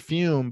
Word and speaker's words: Fum: Of course, Fume is pronounced fume, but it's spelled F-U-M.